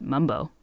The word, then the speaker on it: Mambo: So now Capital City Mumbo Sauce is spelled M-A-M-B-O, though in D.C., many people still pronounce it mumbo.